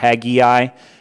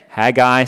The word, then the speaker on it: Haggai: That's the lesson of the year, not Haggai. Haggai, say it right.